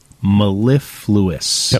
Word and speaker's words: mellifluous: Let me see if I'm pronouncing it right. Mellifluous. – Yep. – M-E-L-L-I-F-L-O-U-O-U-S. – Correct. – M-E-L-L-I-F-L-U-O-U-S.